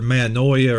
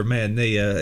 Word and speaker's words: metanoia: The English word repentance comes from the Greek word metanoia or metanoia. It can be pronounced either way.